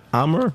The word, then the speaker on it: Amager: Amager, that's how you say it. A-M-A-G-E-R.